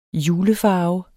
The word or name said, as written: julefarve